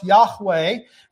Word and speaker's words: Yahweh: In Hebrew, it would be pronounced, some would say it would be pronounced Yahweh.